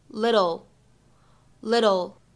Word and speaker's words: little: little 这个单词呢，它在美式发音当中呢存在一个省音的处理，flap t，所以呢，在美式发音当中呢，这个单词我们读成 little，little。